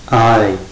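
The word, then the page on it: ai: diphthong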